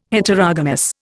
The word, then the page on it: Heterogamous: Indian